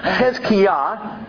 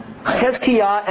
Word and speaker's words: Hezekiah: Hezekiah's name, if you're interested in this, it's actually pronounced Hezkiah. Hezkiah, and it means Yahweh is my strength.